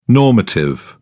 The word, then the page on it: normative: British English